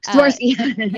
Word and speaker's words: Swarovski: And I know from experience that there's a huge difference between the Swarovski, I'm probably not pronouncing that very well, but Swarovski.